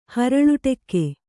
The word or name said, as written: ಹರಳುಟೆಕ್ಕೆ